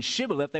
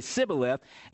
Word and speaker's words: Shibboleth: And they would, like the Arabs would do at that time, and even this time, they would pronounce an SH like an S. So they would give them this test, pronounce this ear of corn. Instead of saying Shibboleth, they would say Sibboleth, and then they would take out their swords and kill them.